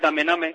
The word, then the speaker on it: Damename: La empresa es Damename, que creo que lo has pronunciado así. Lo he hecho muy en inglés. Vale, pues Damename, ¿no?